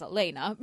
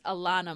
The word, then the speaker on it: Alannah: Now, I would pronounce it as Elena because you're Elena, but I think it's Alannah Myles.